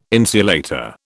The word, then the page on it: insulator: British English